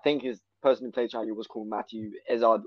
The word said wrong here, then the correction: Lillard: or Lizard. – Lillard. – I forgot the pronunciation. That's the one, yeah.